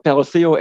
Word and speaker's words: Perdoceo: Wie spricht man das Ganze denn aus? – Also hundertprozentig sicher bin ich mir auch nicht, aber Peroseo Education äh, scheint wohl richtig zu sein.